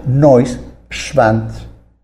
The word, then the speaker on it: Neuschwanstein: Su nombre significa Nueva Roca del Cisne y se pronuncia Neuschwanstein.